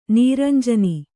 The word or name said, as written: ನೀರಂಜನಿ